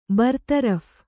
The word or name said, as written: ಬರತರಫ್